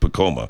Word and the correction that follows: Pacoima: Is that it? – Pacoima. – Oh, Pacoima.